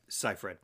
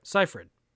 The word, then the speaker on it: Seyfried: 2009, Megan Fox, Amanda Seyfried? Is that how you say it? – Seyfried. – Seyfried.